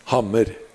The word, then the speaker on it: hammer: But it's pronounced hamr instead of hammer.